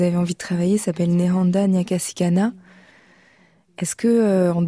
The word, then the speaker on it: Nehanda: vous aider avec la prononciation. Nehanda.